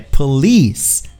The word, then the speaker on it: police: A gente fala polícia é police, police é no fim que se dá a, a, a sílaba tônica, police, P-O-L-I-C-E.